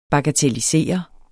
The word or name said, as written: bagatellisere